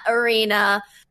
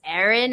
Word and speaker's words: Erin: Simon, E-R-I-N as in Nancy, the letter A-S-I-M as in mother, O-N as in Nancy. And no, my name is not Arena. I know people see that A. It is Erin Ashley Simon.